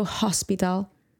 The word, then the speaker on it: hospital: Silent H, everyone. H at the start of a word is not pronounced. Don't go hospital. Doesn't exist.